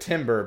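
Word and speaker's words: Timbre: The book pronounces it Timber, but it's spelled like the word Tambor. Anyway, so that happened to Dalinar, everyone else is in Shadesmar.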